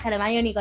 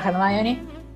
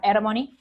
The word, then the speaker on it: Hermione: Primero de no saber pronunciar Hermione Claro. Entonces, yo le decía Hermony, pueden remitirse a nuestros capítulos sobre Harry Potter para reírse Exacto.